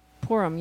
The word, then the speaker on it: Purim: I always mess up the pronunciation of Purim. – Purim, yeah. – P-U-R-I-M, which is part of the... – As in pour him another glass of...